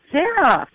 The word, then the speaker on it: Zaroff: It's Zaroff.